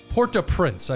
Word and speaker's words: Port-au-Prince: Well, in the capital of Haiti, which is Port-au-Prince, I believe I'm saying that correctly.